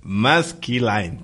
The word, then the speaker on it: Maskelyne: ¿Habían escuchado de Jasper Maskaline? Nunca. No. Maskelyne, tal vez porque no lo pronuncié bien.